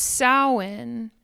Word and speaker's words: Samhain: Someone asked me, what's Samhain? And it's like the Day of the Dead, but for white people. Samhain is spelt Samhain. So it's pronounced nothing like it's spelled.